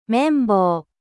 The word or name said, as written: めん棒